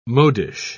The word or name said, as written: modish